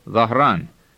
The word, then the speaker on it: Dhahran: Now listen again to the name Dhahran. And notice that the H before the rolled R is clearly pronounced.